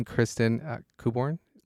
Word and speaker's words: Kristin Kuborn: So today, I want to welcome Kristin Kuborn.